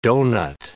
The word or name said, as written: doughnut